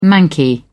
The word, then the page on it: manky: British English